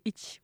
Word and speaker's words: hiç: Voilà, le C c'est dit, c'est Ich, voilà, pour info, pour les personnes qui sont intéressées par cette info.